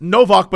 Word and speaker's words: Nowak: And in Poland, it's Novak, but it's spelled Nowak, but the W's are V's in Poland.